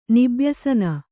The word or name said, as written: ನಿಬ್ಯಸನ